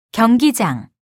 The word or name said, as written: 경기장